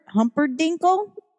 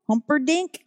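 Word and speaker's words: Humperdinck: How do you pronounce that word? Engelbert Humperdinkle? Humperdink? – Yeah, Engelbert Humperdink. – Oh, there you go.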